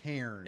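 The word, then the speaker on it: Hârn: And I also picked up, you know what Harn is? Am I even pronouncing that? Is it Hairn? Or I believe that would be Hairn.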